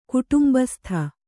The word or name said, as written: ಕುಟುಂಬಸ್ಥ